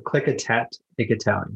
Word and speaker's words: Clikatat Ikatowi: Can you talk about that formation of, and if I'm pronouncing and mispronouncing it, it's also my stuttering, but Click Attack, Ikatowi, right? – Pretty close. Yeah. Yeah. That's pretty much it. Clikatat, Ikatowi.